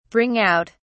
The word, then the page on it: bring out: British English